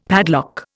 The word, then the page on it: padlock: Indian